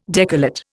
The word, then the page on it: decollate: Indian